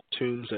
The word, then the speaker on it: Toombs: So T-O-O-M-B-S, and the first name is Colt, so at Colt Tombs at social media,